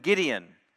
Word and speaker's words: Gideon: That pattern happens with Othniel, then Ehud, then Shamgar, then Deborah and Barak, and then Gideon, whose name conveniently rhymes with Midian, who were the oppressors in that particular story we talked about over the past couple of weeks.